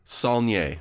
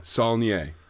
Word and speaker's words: Saulnier: How do you pronounce Jeremy's name? Jeremy Saulnier. – Saulnier. – Saulnier. Yes, French.